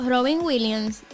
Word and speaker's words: Robin Williams: Porque es que Nosotros vimos una película, creo que fue la última película que hizo Robin Williams.